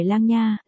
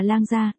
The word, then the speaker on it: Lang Gia: Cô gái cười lắc đầu, ánh mắt tinh nghịch, không phải Lang nha, là Lang Gia, đồng âm khác nghĩa, Lang Gia của Lang Gia Thai.